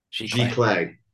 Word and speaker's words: giclée: How did you pronounce it? G Clay? – G Clay, yes.